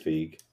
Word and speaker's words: Feig: I would say Feig or Feig. I think it's Feig. – Yeah, Feig. – Paul Feig, maybe. – F-E-I-G, for those.